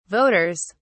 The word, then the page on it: voters: British English